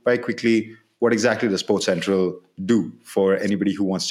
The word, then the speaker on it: SportCentrAll: Like when you go to, so it's spelled Sports Central, like A L L, but pronounced Sports Central, as Philip said.